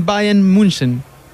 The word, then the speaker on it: Bayern München: ¿nos regalaría usted cuál es la correcta pronunciación del Bayern Múnich? Hay varias, pero pues eh, FC, Bayern München.